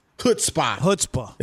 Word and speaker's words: chutzpah: Max, I like your chutzpah. Chutzpah. Did I say it right? Chutzpah. – That's right. Yeah.